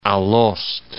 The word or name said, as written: Alost